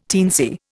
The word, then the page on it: teensy: Indian